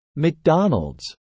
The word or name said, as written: McDonalds